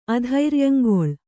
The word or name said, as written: ಅಧೈರ್ಯಂಗೊಳ್